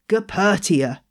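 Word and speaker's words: Goeppertia: Calathea, most of the species in this genus have been moved to another genus called Gapertia. So did you know how to spell that? It's G-O-E-P-P-E-R-T-I-A.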